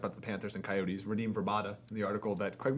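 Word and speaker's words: Radim Vrbata: Radim Verbata. That's how it's pronounced.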